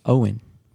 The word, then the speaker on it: Owyn: Is it? Owen. – Owen? – Owen. – Okay, if I'm pronouncing it wrong. O-W-Y-N.